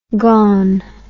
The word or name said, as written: gaun